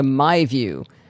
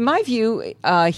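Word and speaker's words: MyVu: Well, today he stopped by my house to show off his latest gadget, the MyView.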